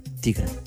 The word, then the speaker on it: Tigra: E o que é que mudaram em relação à imagem do Opel Trigger? Tigra. – Até que ponto é que falhas na pronúncia do nome?